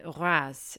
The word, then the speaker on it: Reims: Now, if you want to go and visit this champagne house, you can find them in Reims. Now, it's spelt R E I M S, but you don't pronounce the M.